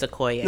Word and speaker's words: Okoye: Okay, but Okoye, because I'm country, but it's Okoye.